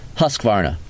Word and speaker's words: Husqvarna: Husqvarna. All right? So that's the proper pronunciation. Make sure you guys know that.